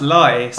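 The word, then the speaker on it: Leigh: Yeah, it's actually pronounced Lye. So there's quite a few of these places in the country, and most of them are pronounced Lee, but the one I grew up in is called Lye.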